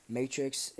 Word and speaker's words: MTRX: Matrix is spelled M T R X in all caps underscore capital S A G A G E 2140.